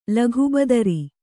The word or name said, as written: ಲಗುಬದರಿ